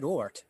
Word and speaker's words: Ort: Wart. It's O-R-T. It's not very hard to pronounce. – Yeah, I said Ort. – Ort, all right. Well, you asked me how to pronounce it. It's the simplest name ever.